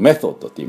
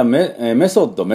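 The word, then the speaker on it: method: ですから、まあぜひですね、まあ、日本でもそのたくさん方法を重ねて、改良を重ねて良くなったと、そのメソッドって言いますけど、作っていくメソッド。また、テクニックが、テクニック良くなったということなんです。じゃあこの発音を今日はやっていこうかなと最後に思います。じゃあメソッド、日本でもメソッドっていうのは日本語英語になってますよね。でも発音がちょっといけてないんですね。これメの後は TH なんです。でメソッド、メソッド、メソッドっていうふうに発音します。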